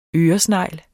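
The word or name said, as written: ØRESNEGLE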